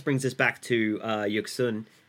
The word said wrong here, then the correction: Yeon-suk: who I think I just pronounced his name incorrectly. – Are you talking about Yeon-suk, the bad rich guy? – Oh, man, I did it so bad. Yeon-suk.